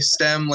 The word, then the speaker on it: STEMN: Is that correct to just say STEM like you would use without the N, or you have your own pronunciation for it? – No, I'm glad you guys nailed it first time. It's tricky being a silent N. People are like, do I pronounce it? Do I not say it? Yeah, we're STEM.